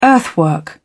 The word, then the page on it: earthwork: British English